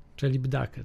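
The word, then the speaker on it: Celibidache: Sergiu Celib Deik, Celib Dake. Celib Dake, tak? Tak się wymawia. Celib Dake. Celib Dake, to już zapamiętam.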